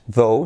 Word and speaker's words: though: He's very fast, though, se usa mucho, ¿vale? En inglés. Y la pronunciación sería though. Letra semiclave TH, though, though, though.